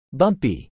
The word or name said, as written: bumpy